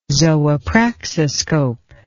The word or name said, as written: zoopraxiscope